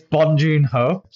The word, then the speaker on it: Bong Joon-ho: You can't say his name, can you? Jong Boon's son or something. – No, silly. It's Bong Joon-ho. So Bong Joon-ho.